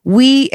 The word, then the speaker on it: OUI: And then I also grabbed this place called, how do you say that? OUI? – Yeah. – We, and I guess they're based off of Melrose, so they are local.